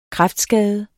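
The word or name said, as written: kræftskade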